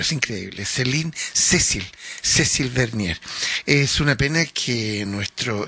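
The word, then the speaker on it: Cécile Verny: Letra de Cecil, Céline había dicho antes, Cecil Vernier, tiene un acento en la E, Cecil Vernier, y del pianista Andreas Erhinger.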